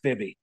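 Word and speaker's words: Bibee: Bibby or Bibby? – We'll go with Bibby.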